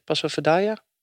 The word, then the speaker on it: Passo Fedaia: En dan de 20e etappe naar de Passo Pordoi met de finish op, mag ik dit uitspreken? Passo Fedaia? Zo? Oké.